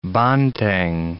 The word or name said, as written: banteng